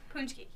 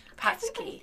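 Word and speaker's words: pączki: Poonchki. Yeah, I thought it was patch, patchki. thinks that. Okay, it's a Polish word and it's pronounced poonchki.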